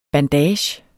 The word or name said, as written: bandage